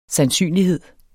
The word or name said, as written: sandsynlighed